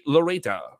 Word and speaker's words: Loureda: Valerie Loureda.